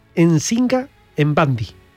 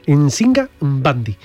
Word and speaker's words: Nzinga Mbandi: de gestionar ejércitos, pero fijaros, se llamaba Nzinga Mbandi. Así hay que pronunciar las M, ¿eh?, y las N. Nzinga Mbandi.